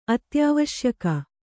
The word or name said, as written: ಅತ್ಯಾವಶ್ಯಕ